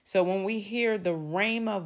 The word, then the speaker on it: rhema: And so again, we see here, there's another word study, Strong's Concordance 4487, and that's pronounced Rhema.